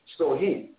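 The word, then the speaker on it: Samhain: And research the word Shahan. And the pronunciation is Sohim.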